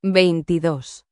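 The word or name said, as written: veintidós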